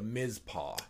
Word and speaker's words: Mizpah: So there's a hotel in Tonopah, Nevada called the Mizpah. – Right, and that's spelled M-I-Z-P-A-H.